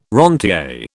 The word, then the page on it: rentier: British English